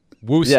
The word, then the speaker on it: Worcester: No, Worcester with a W. Worcester. Rhymes with rooster. Worcester.